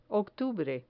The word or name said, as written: octubre